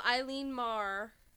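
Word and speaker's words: Eilean Mòr: So it's off the west coast of Scotland, island of Eileen Mar. Is that how yeah, that's how you say it, yeah.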